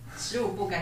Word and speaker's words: 十五步: 嗯，好，十五步，感觉应该大家都会念成五十步吧。